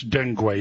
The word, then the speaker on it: dengue: I don't know how to pronounce dengue. Is it D-E-N-G-U-E, the mosquito thing? – Dengue fever.